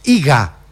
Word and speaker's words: Iga: Dije bien Iga, ¿no? – Sí. – ¿Lo dije bien? Iga.